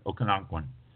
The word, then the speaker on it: Okanagan: But Okanagan, again, it's for the fact that I probably destroyed the pronunciation of the word. It's spelled O-K-A-N-A-G-A-N.